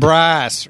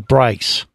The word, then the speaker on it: Bryce: Bryce rhymes with rice. – No, Bryce.